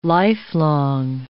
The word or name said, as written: lifelong